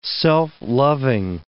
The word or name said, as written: self-loving